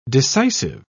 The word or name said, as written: decisive